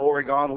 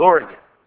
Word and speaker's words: Oregon: you need to understand we are not Oregon. We haven't gone anywhere. It's Oregon. Oregon is how you pronounce it.